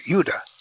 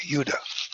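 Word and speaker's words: Judah (יהודה): The Hebrew is Judah, pronounced like a Y, Judah.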